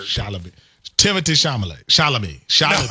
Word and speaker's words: Chalamet: Is it Timothy Chalamet? – He said Chalamet.